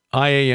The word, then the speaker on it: Luwian: Luwians, and I've also heard it pronounced Luwian. So, Luwian is correct.